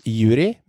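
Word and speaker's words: Yuriy: Men det interessante at han, Juriv Jakovenko, 28, signert ettårskontrakt for Hamkam, han skrives Juri med Y til slutt, men Y-en en stum Y og uttales med I.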